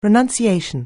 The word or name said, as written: renunciation